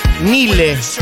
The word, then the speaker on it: Nile: No, bueno, no es ninguna de las dos, es Nile, Nile Rogers.